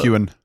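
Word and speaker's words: Heughan: And it's got star studded cast Joely Richardson, Niamh Algar, oh, Sam Hugan, is that how you pronounce it? From out of. – Sam uh, Hewen. Hewen.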